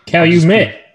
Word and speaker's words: Calumet: Calumet.